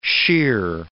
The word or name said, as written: shear